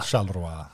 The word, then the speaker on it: Charleroi: Charleroi. Charleroi. Ja. – Snyggt, från Belgien. Snyggt uttalat.